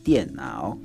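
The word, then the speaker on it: dianao (电脑): Computer ni dianao.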